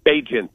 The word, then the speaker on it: Bagent: So the backup quarterback is Tyson, how do you say his name, Bagent? – Tyson Bagent. – Bagent.